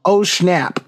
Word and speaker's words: Schnapp: Like the guy that plays Will Byers, Noah Schnapp. Is that how you say his name? – Mm-hmm. Schnapp.